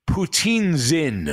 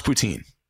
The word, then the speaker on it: poutine: He says it's not pronounced poutine, but rather poutine-zin or something like that. Poutsinn, because it's French Quebec. It is pronounced poutine.